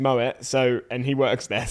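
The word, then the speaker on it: Moët: Bottle of Moet, which by the way, for anyone out there listening who may be unaware, it is pronounced Moet. I've had confirmation from someone at Moet. I always thought it was Moe.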